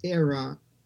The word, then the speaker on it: AERA: And the model you work on is called the Autocatalytic Endogenous Reflective Architecture, which is abbreviated AERA, and you pronounce ERA.